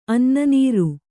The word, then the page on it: ಅನ್ನನೀರು: Kannada